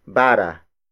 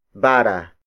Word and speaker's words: bara: To measure land distance, however, Filipinos used an instrument called bara. Bara. Bara. Bara is spelled as B-A-R-A.